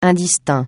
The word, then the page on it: indistinct: francais